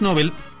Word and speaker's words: Nobel: A ver, los premios Nobel, en contexto te pongo un poquito.